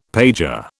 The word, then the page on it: pager: British English